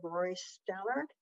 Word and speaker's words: Rory Stallard: Let's move on to the next presenter, Rory Stallard. – Rory. Yes, Madam Chairwoman, thank you. Yep, yeah, you've got the pronunciation right.